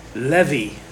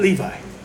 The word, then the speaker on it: Levi: Levi, not Levi. Levi. Levi is how this name is pronounced biblically. So in the United States, we say Levi, kind of like the brand name for the jeans, Levi's. The rest of the world, however, and in the biblical accounts, the proper pronunciation is Levi.